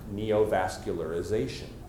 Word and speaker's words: neovascularization: How many syllables? Guess 8. 8. Okay? So again, this word is meaningless to me until I hear 8 syllables. It's pronounced neovascularization.